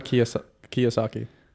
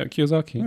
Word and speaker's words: Kiyosaki: Robert Kiyosaki. Yeah, Kiyosaki.